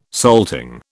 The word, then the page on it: salting: British English